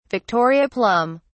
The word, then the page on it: victoria plum: British English